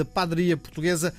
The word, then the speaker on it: Padaria Portuguesa: Estou a pronunciar mal, não é? Padaria. Como é que pronuncia? Padaria, padeiria. Como é que se diz? – Olá, Miguel. Obrigada pelo convite. Padaria portuguesa. Padaria, não é? – Eh, as pessoas da Madeira é que dizem padaria.